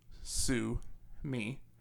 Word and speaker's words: Sumi: Say, sue, me. – Mm-hmm. – The name of the singer is Sue Me, S-U-M-I.